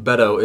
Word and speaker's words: Beto: So let's assume Beto is running for president. – And now we're pronouncing it correctly.